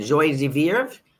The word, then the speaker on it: joie de vivre: How do you say that? Joie de vivre? De vivre? Good.